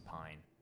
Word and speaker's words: Pyne: It's going to be Ottara, O-T-T-A-R-A. Middle name is Sacha, S-A-C-C-A. Last name is Pyne, P-Y-N-E.